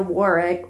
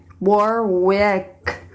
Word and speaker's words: Warwick: But it is not, because we did not go to Warwick. I didn't say it right. Warwick, Rhode Island.